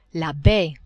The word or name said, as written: baie